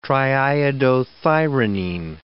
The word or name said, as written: triiodothyronine